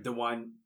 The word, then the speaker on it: Dwyane: D-W-Y-A-N-E. That's right, right? – Dwayne. Yeah. It's like Dwayne. Dwayne. Dwayne. – Dwayne. – Dwayne. – No, you gotta say it's Dwayne.